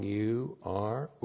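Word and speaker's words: ur (עור): Ayin, long U, R, ur.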